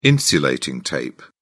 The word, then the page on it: insulating tape: British English